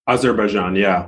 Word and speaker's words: Azerbaijan: Azerbaijan.